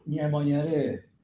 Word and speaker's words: Ñamoñaré: Ñamoñaré. – Así lo pronuncia él. Nosotros lo pronunciaríamos, Artigas, Muñeré, claro.